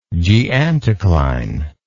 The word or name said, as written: geanticlinal